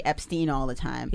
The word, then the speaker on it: Epstein: So if it's E-I, it's pronounced I, like Epstein.